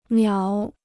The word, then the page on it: 秒: miǎo